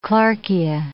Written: clarkia

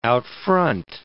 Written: out-front

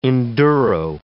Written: enduro

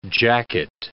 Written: jacket

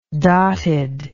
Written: dotted

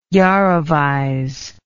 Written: jarovize

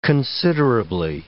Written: considerably

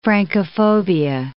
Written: Francophobia

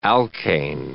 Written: alkane

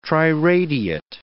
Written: triradiate

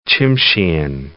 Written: Tsimshian

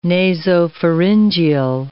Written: nasopharyngeal

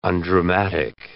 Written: undramatic

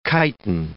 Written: chiton

